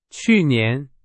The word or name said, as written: 去年